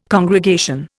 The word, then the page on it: congregation: Indian